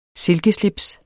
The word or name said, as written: silkeslips